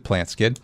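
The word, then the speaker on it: Plantskydd: It's spelled plant SKYDD. So, and you pronounce it PlantSkid.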